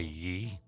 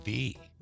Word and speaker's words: ye: Do you know that you're pronouncing it wrong when you say ye? It's actually the. – Huh. – So it's actually pronounced the in medieval English. People used a Y to abbreviate TH.